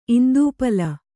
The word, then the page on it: ಇಂದೂಪಲ: Kannada